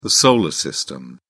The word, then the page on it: solar system: British English